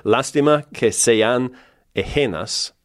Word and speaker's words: Lástima que sean ajenas: The album is called, and bear with my pronunciation, please. I'll work through this. Lástima que sean ajenas. – Ajenas. Yeah, but you said it perfectly.